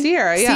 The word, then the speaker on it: Sierra: Am I saying that correctly? – Sierra, yeah.